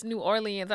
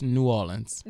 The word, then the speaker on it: New Orleans: Because she sure ain't from New Orleans. – She must be from Kenner. – She from Baton Rouge. And then Shayna was like, it's New Orleans.